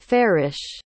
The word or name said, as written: farish